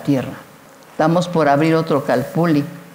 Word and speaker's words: Calpulli: pero es Calpulli, se pronuncia Calpuli, viene del náhuatl.